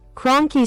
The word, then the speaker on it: Kroenke: Kroenke Sports and Entertainment.